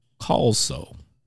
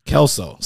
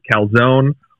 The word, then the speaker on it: Kalsow: Yeah, it's just like that 70s show or Scrubs, if you've seen those at all. They have the iconic Kalsow characters. It is spelled a little bit different. So I have been called Calzone or Coleslaw before. But no, Kalsow.